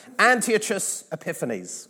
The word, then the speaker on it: Antiochus Epiphanes: And he was called, and this is the exact way he would have pronounced his name. Okay, so just trust me on that. Antiochus Epiphanes.